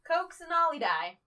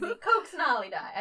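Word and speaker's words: Coccinellidae: And the scientific name I'm going to attempt to say is Coxinolidae. – Ah, of course. – So easy. Coxinolidae.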